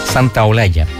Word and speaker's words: Santaolalla: También producido por el argentino Gonzalo Santaolalla, que dicho sea de paso, el fin de semana, me cuesta pronunciar ese apellido, es Santaolalla.